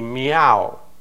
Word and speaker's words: Miao: And so in this little town, we have 28 students from three of these minority groups, the Miao group, okay? Just like the cat's meow. It's not spelled the same way, but it's pronounced the exact same way.